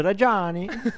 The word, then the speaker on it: Reggiani: Ora visto che è italiano, questo si chiama Sergio Reggiani. Reggiani si chiama. – È un emiliano, se non sbaglio, immigrato e naturalizzato francese. – Quindi si pronuncia Sergio Reggiani. – Sì, esatto, così.